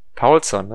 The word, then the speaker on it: Paulsson: Ich weiß noch, wo an der Ecke der Paulsson oder wie spricht man ihn richtig aus? Du hast es mir gestern beigebracht. – Paulsson, ne? Also zumindest Paulsson. Hat das der Schalke-Kommentator mehrmals in den Testspielen gesagt, als er es dann herausgefunden hat und war da extrem stolz drauf.